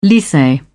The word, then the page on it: lycee: British English